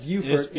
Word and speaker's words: Beaufort: Well, actually, in South Carolina we call that Beaufort. In North Carolina, it's, it's pronounced Beaufort.